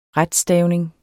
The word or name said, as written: RETSTAVNINGER